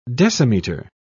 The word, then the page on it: decimetre: British English